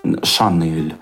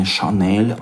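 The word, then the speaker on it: Chanel: Или вот такие доебочки типа Шанель. И тоже начинают даются. Вообще-то правильно не Шанель, а Шанел.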